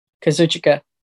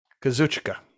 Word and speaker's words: Kazuchika: Kazuchika. Kazuchika.